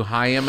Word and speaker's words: Haim: She's like, it's Haim. Yeah, it's good though, right? Like, oh, I was so close. I knew the album, I knew the song, and I mispronounced the name by one syllable.